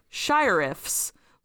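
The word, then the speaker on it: Shirriffs: Yeah, my audiobook pronounced it sheriff. – I don't know how else you would pronounce it besides sheriff because it's got the two R's, but I think it's compounded sheriff and shire together.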